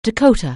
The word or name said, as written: Dakota